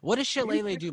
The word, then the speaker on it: shillelagh: I'm going to cast a bonus action shillelagh. I think that's how you say it. – Okay. Yeah, shillelagh.